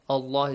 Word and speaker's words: Allah: Allah is pronounced heavily.